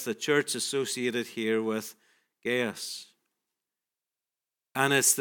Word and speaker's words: Gaius: I've heard it mostly Gaius, so we'll stick with that.